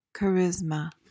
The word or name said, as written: charisma